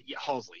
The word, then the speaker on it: Halsey: There is another Youngblood song that he did with, is it Halsey? Is that how you say her name? – Yes. – Halsey. And Travis Barker. Yeah. Halsey, yep. Yeah.